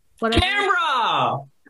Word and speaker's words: Canberra: Is it, I don't know how to pronounce it. Canberra? – Canberra! – Okay, yeah, sure.